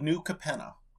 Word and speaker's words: New Capenna: The streets of New Capenna.